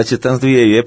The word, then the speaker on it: Titãs do Iê: Eu falei certo aqui, era Titãs do Iê, não era? – Era Titãs do Iê, porque naquela época, isso 82, né, come começou a banda, a gente já achava que era uma releitura do Iê, né, naquela época.